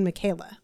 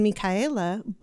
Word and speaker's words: Micaela: My name isn't even Michaela. My name is Michaela.